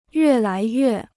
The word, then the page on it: 越来越: yuè lái yuè